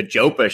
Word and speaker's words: Jopa: And obviously one of the Jopa show, I've been calling it Joppa this whole time, but it sounds like it's pronounced Jopa.